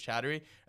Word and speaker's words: Chattery: But then they pronounce my last name, like, my last name's Chattery. And they would butcher it completely.